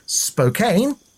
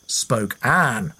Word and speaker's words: Spokane: Spokane is how I said the city's name. Christy says it's pronounced Spokane, as in Queen Anne."